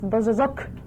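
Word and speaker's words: Bōsōzoku: In real life, I think it should be pronounced Bozozok. Mm-hmm. in Japanese, and it means a motorcycle thug or a noisy man on motorcycle.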